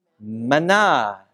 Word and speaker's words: manna: In Hebrew, that's pronounced manna, right?